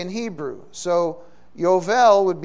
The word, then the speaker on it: Yovel: It was the B was actually pronounced as a V, Yovel.